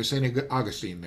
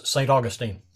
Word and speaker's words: St. Augustine: Do you say St. Augustine or St. Augustine there? – We pronounce it like the grass, St. Augustine. – St. Augustine, okay.